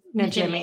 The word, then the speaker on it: Najimy: How do you say her name? Najimy? – Najimy. Exactly. Yeah.